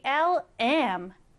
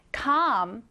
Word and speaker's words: calm: 이번에는 calm, calm입니다. 이 calm은 고요한 혹은 침착한이라는 의미고요. L은 거의 소리가 나지 않습니다. 그래서 그냥 calm 이렇게 읽어요. 자, 스펠링을 기억하시고 쌤이랑 같이 오른쪽에 정확하게 써보겠습니다. calm, C, A, L, M.